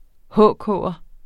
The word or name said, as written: HK'er